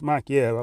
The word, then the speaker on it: Makhachev: Like, if you haven't, like Islam Makhachev, for instance, Makhachev, I believe that's how you're supposed to pronounce it.